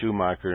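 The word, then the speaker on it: Shoemaker: If you look up on the internet and you look at the pictures of Schumacher-Levy 9, it's shoe just like a shoe you'd wear on your foot. It's spelled Schoemacher. Levy, the second word is L-E-V-Y, named after Gene Schumacher and David Levy, who discovered the comet.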